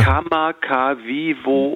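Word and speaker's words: Kamakawiwoe (Kamakawiwoʻole): Israel, wie spricht man den aus? Kamakawiwiwoe? – Oh, Ulle. Würdest du mich jetzt so... Ich habe es jetzt gerade auch lustigerweise mir ja nochmal vorgeholt. Ja. Kamakawiwoe. – Ole, genau so.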